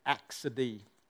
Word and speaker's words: acedia: One of the enemies of the Christian is this thing that the ancients called axidy. Has anyone heard of axidy? It's not even spelt like it's said. It's spelt A-C-E-D-I-A. You would think it's acedia, right?